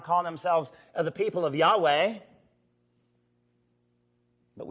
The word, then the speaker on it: Yahweh: Now, sometimes Yahweh is mistransliterated as Jehovah. But the actual closest pronunciation of God's name is Yahweh.